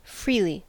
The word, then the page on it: freely: American English